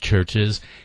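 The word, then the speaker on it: Chvrches: Churches, and it's spelled with a V instead of a U, so it's C-H-V-R-C-H-E-S, but pronounced Churches.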